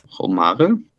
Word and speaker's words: Romare: Auch eine Lieblingsmusik von Ihnen, Motherless Child von Romer oder Romare. Wie spricht man die aus? – Romare.